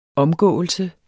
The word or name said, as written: omgåelse